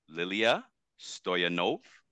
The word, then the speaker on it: Liliya Stoyanov: And without any further ado, we have with us today Liliya Stoyanov.